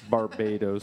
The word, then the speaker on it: Barbados: The Barbados of the world. I always get that name wrong. Is it Barbados? Barbados. But good try.